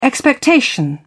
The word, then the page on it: expectation: British English